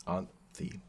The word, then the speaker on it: Anthy: know how to pronounce her name. Anji? Anthy. Anthy. I was gonna say I remember yeah. a th in it.